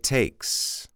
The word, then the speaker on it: takes: Are you pronouncing the S there? It takes. I'm going to start to call that the magic S, the S that will make your English fantastic if you remember to pronounce it.